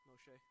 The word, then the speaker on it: Moshe: Did I say that right, Danny? Moshe? Okay, good.